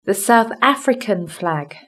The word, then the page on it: the south african flag: British English